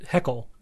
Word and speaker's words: Haeckel: So it's, you mentioned, is it pronounced Heckel? – I thought I was said Heckel. Ernst Haeckel.